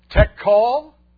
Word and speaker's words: Tekel: The words are pronounced Mene, Mene, Tekel.